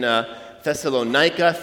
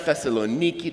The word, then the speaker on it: Thessaloniki: 2 Thessalonians is the second letter written to the same city, same church in Thessalonica, Thessaloniki, depends on where you, how you're pronouncing it, modern Greek or ancient Greek.